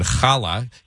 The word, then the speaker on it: challah: And you tasted challah for the first time, and I'm pronouncing it, I guess, with the Jewish pronunciation, challah, because that's what I say. – There you go. – But I'm sure challah is okay, too.